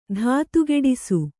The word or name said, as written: ಧಾತುಗೆಡಿಸು